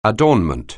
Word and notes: adornment: anglais

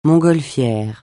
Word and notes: montgolfière: francais

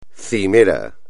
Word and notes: cimero: espagnol

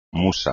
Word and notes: musa: espagnol

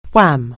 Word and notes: wham: anglais

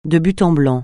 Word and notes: de but en blanc: francais

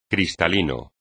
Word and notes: cristalino: espagnol

